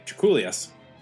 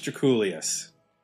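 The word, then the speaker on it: Drakoulias: Draculius, because in the Beastie Boys song B-Boy Bouillabaisse, which is on the album Paul's Boutique, they actually name-check George Draculius, and this is how they do it.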